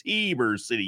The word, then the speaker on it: Heber City: He throws a couple extra E's in there just to help me remember how to properly pronounce it. It's Heber City, Utah.